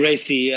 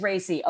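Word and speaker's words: Raci: Paul Racy. – It is Racy.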